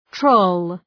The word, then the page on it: troll: trəʋl